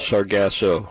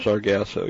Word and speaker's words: Sargasso: It's like sawgrass something, but it's not how you say it. – Sargasso. – Sargasso? – Sargasso, yeah. Sargasso seaweed.